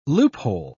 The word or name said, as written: loophole